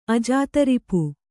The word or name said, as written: ಅಜಾತರಿಪು